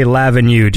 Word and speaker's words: L'Avenue: How do you say Lavenue? Do you make a distinction, go Avenue? Or I'm constantly corrected on that by my German wife. From what I understand, it is Lavenue, but I always say Lavenue because I'm British, ain't it? – Right.